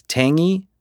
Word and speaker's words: tangy: The other two words that I had trouble with was tangy. I kept pronouncing the G, and I guess I'm still doing it. tangy. I kept saying tangy, and it was not correct.